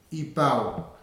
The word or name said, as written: ipau